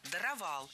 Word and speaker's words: даровал: А вот слово «даровал» имеет другое ударение. Правильно, «даровал». Опять же, ударение ставим за корнем.